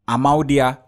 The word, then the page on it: AMAUDIA: Efik